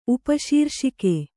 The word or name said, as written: ಉಪಶೀರ್ಷಕ